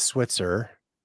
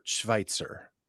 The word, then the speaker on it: Schweitzer: Everybody mispronounces it as Switzer. – Oh. – And I say it's like Dr. Albert Schweitzer.